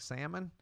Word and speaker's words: Salmon: It is spelled like salmon.